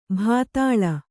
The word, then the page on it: ಭೂತಾಳ: Kannada